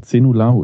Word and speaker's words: Zejnullahu: Erol und dann Zenulahu ist wie 10-0. Äh, Ahu. 10-0 Ahu.